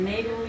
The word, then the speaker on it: Neru: Sí, es Neru Black. Pero bueno, eso viene de ahí. – ¿Neru o Neru? – Neru. – Neru, con el acento en la E.